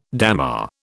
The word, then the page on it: dammar: British English